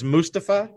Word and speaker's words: Mustafa: But it is Mustafa? – No long U. It's a soft U.